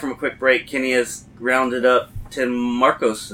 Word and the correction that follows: Marcum: Did I say that right? – Tim Markham. – Markham. – Markham. – See, I said it wrong.